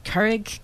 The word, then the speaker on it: Keurig: So if you want to pronounce it like a real Dutch person, Keurig.